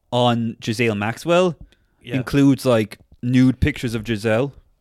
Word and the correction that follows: Ghislaine: Oh, her name's Ghislaine. – Oh, what's they call her? Ghislaine, okay. – Jazelle, but you think the mix-up is because the way it's spelled, some people say Ghislaine, but you didn't do that either. No. You called her Jazelle.